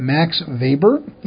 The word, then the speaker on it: Max Weber: His name is Max Weber. I know it's spelled W-E-B-E-R, but those Germans generally pronounce their W's in this position as V's. So we've got Max Weber.